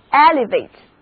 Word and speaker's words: elevate: 如果我们在词根 lev 的前面加上表示出的前缀 e，然后后面呢再加上 ate 这个动词后缀，摆在我面前的这个单词就是 elevate， elevate， elevate，表示使什么什么提升。所以单词 elevate 的意思就是提升、举起。第一个字母 e 读作 a，而元音字母 a 要读它的本音 a，重音在第一个音节 elevate， elevate，它是一个动词。